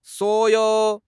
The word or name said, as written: suo3 you3